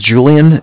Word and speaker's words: Julian: My name's Julian. It's J-U-L-I-A-N. – Mm-hmm. – I'm calling from Bank of Cardiff. Cardiff is spelled C-A-R, D as in David, I-F-F as in Frank Frank.